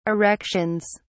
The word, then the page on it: erections: British English